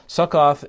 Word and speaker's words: Succoth: And then he said to the men of Succoth, Succoth is how you'd pronounce it, I believe, in Hebrew.